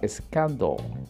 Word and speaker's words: scandal: Tenemos la palabra scandal. Scandal. Se escribe escándalo con s c a N-D-A-L. Scandal, scandal. Una vez más, la A y la L se pronuncia como una O-L.